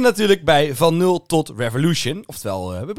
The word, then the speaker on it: Revolution: Ja, hoe spreek jij Revolution uit? – Revolution. – Revolution, oké.